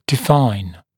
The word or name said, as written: define